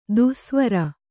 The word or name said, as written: ದೆಸೆವರಿ